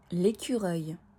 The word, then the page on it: L'écureuil: French